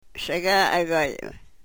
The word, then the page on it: segaagoyem: yurok